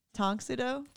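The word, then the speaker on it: Tang Soo Do: Tong Sudo. – I knew I said it wrong. I don't care. Okay. Yeah, I do care. I'm sorry. Tong Sudo.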